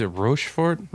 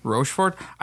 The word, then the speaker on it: Rochefort: I think it's Rochefort. – Rochefort. I don't speak Belgian. – I don't either. I've called it Rochefort. – Okay. Rochefort. Rochefort. And this is the 8. – I pronounced it like an American. Yeah. I looked at it and pronounced it how it says it. So I said Rochefort.